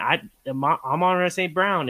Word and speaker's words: Amon-Ra St. Brown: And Amon-Ra St. Brown is, or I said that right, yeah.